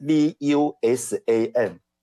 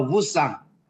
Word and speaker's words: Vusan: 是，来先问一下我们的两位老师哦，请问一下，这个你们这个课后班哦的名字很特别，它的拼音是 V U S A N，这个发音要怎么发音？先教我好不好？ – 这叫 Vusan。